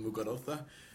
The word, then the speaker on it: Muguruza: Gabin Muguruza.